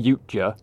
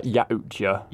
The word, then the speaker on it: Yautja: Some people say Yuja. – Yuja. – Some say like Yautja. – Yautja.